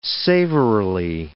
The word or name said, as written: savorily